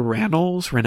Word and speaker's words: Rannells: Rannells. I don't know how to pronounce his last name. – Andrew Rannells. – Rannells.